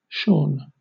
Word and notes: shorn: British English

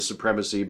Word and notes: supremacy: American English

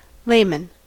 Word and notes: layman: American English

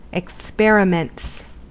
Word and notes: experiments: American English

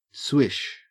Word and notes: swish: Australian English

